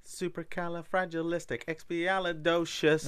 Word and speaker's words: Supercalifragilisticexpialidocious: Supercalifragilisticexpialidocious. – Whoa, we said it right this time.